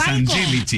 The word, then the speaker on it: Angelici: La pronunciación es Angelici.